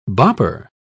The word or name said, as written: bopper